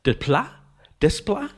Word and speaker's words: Desplat: How you pronounce Alexandre Desplat? Desplat.